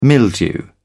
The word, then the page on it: mildew: British English